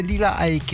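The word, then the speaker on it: Lila Iké: En fait, il faut prononcer Lila Ike, me semble-t-il.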